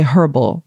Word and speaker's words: herbal: So to me, that's the funniest thing that we talk about herbal teas here in America, because in South Africa, they would say herbal. – Interesting. – Yeah, they would pronounce the H, but they wouldn't serve it to you, especially not at church.